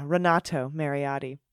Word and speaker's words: Renato Mariotti: But yeah, Renato Mariotti.